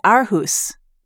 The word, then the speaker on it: Aarhus: So is it Aarhus? – Yes.